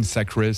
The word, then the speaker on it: Thacray: Donc Imagine Sacré, si on fait semblant de le dire à l'anglaise, donc ouais. Imagine Sacré. Sacré, ça s'écrit T-H-A-C-R-A-Y, qui est une musicienne incroyable.